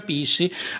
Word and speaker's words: PSSI: Ho fondato un partito, il Partito Super Sovranista Italiano, PSSI, si pronuncia Pissi.